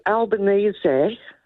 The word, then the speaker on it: Albanese: If it were Albanese, pronounced correctly.